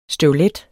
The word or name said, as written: støvlet